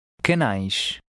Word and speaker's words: canais: The AI combination often sounds like a long I, as in ice. Try to read number 10. – Canais.